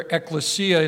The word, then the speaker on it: ekklesia: Ekklesia is spelled E-K-K-L-E-S-I-A. E-K-K-L-E-S-I-A, ekklesia.